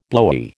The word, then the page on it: blowy: American English